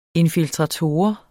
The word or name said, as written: infiltrator